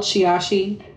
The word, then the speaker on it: Shiashi: You know, and I would be like, it's exactly how it's spelled, Shiashi.